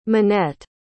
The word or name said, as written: minette